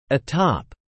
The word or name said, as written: atop